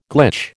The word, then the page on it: glitch: American English